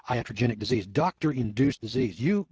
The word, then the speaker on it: iatrogenic: All right, we've been talking about this thing of iatrogenic disease.